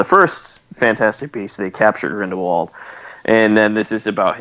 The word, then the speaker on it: Grindelwald: Yeah, the Germans pronounce it W's with a V, folks. – Yeah, so it's Grindelwald.